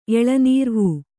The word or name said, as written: ಎಳನೀರ್ವೂ